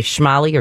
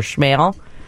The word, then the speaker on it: Schmale: You know, every time you say schmally or schmale. – I don't even know what that is. What is that? – It's a road in the Western Burbs. – Okay. – S-C-H-M-A-L-E. – All right. – So a lot of people say schmally. – Schmally. – Or schmale. – Okay. – Yeah, and whichever way you say it, you will always have a texter saying you said it wrong.